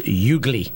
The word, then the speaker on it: Ugli: Some say also perhaps maybe an orange to create the U-G-L-I, the ugly fruit, or perhaps as it's pronounced, ugly.